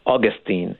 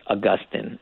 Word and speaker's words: Augustine: Augustine, although it is also often pronounced Augustine, and I myself switch between those two in unpredictable ways. But Augustine, I think, is what I often end up saying most.